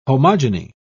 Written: homogeny

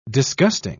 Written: disgusting

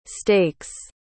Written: steaks

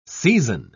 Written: season

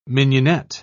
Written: mignonette

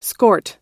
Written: skort

